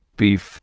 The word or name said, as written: beef